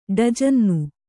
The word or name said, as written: ಡಜನ್ನು